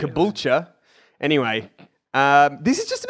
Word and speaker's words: Caboolture: Caboolture, there you Caboolture? – Go.